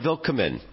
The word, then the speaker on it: Willkommen: Willkommen, if I'm saying that correctly. – Yeah, that's pretty close.